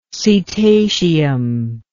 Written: cetaceum

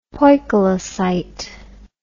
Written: poikilocyte

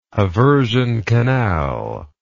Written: haversian canal

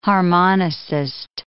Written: harmonicist

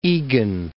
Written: Eagan

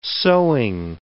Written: sewing

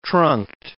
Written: trunked

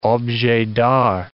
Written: objet d'art

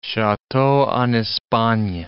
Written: chateau en Espagne

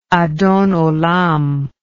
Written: adon olam

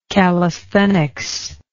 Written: callisthenic

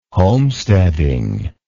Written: homesteading

